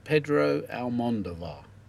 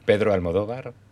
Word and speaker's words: Pedro Almodóvar: So, Pedro Almodóvar. Did I pronounce nearly? Was that good? – No. – Oh, you say it. – Pedro Almodóvar. – Pedro Almodóvar. – Well, oh. – You're getting close.